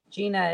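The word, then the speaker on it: Jeana: Gina is spelled J-E-A-N-A. I'm a weird one. It's like jeans with an A.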